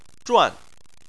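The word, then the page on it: zhuan: tone 4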